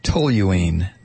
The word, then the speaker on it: toluene: Bob in Miami says it's pronounced toluene, the organic compound toluene.